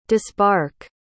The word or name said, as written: disbark